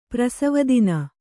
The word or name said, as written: ಪ್ರಸವದಿನ